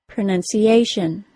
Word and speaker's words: pronunciation: Words that include T-I-O-N, as well as S-I-O-N, are stressed on the syllable before the T-I-O-N. That means in the word pronunciation, it is the tiny syllable that only contains the letter A that is stressed, pronunciation.